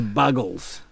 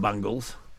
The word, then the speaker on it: Buggles: It was the Buggles, not Bungles.